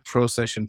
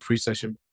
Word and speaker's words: precession: Okay, we're always looking at the precession of the equinoxes as astrologers, which is, it's not procession forward, but precession, which is these big astrological agents.